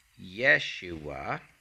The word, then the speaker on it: Yeshua: Jesus' name is spelled, let me get my little thing up here, it's spelled Yeshua. Like this, in English letters, it would be Y-E-S-H-U-A, Yeshua.